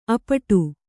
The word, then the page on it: ಅಪ್ಪಟೆ: appaṭe